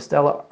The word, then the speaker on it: Stella: Stella, that's how you pronounce that? – Stella. – We'll take your word for it.